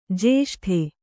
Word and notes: ಜೇಷ್ಠ: jeṣṭha

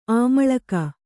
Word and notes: ಅಮ್ಲಿಕೆ: amlike

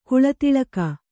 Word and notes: ಕಿಳ್ದಾಳಿಗೆ: kiḷdāḷige; Kannada

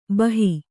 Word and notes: ಭಾಹೊ: Kannada

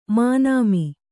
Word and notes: ಮಾನೋಮಿ: mānōmi

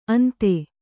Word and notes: ಅಂದಿ: kannada

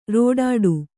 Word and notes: ರೆಡ್ಡಿ: Kannada

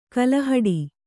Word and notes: ಕಲ್ಲುಹೆಟ್ಟೆ: Kannada

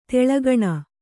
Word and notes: ತೇಲುಗಣ್ಣು: tēlugaṇṇu

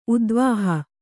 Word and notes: ಉದ್ವಾಹ: udvāha

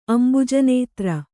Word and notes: ಅಂಭೋಜನೇತ್ರೆ: ambhōjanētre

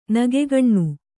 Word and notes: ನಗೆಗಣ್ಣು: Kannada

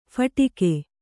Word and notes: ಫಟಿಕೆ: phaṭike

